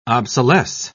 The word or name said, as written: obsolesce